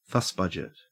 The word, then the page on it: fussbudget: Australian English